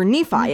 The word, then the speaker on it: Nephi: Um, N E P H I is pronounced Nephi, or Nephi, excuse me, Nephi, like Delphi, okay.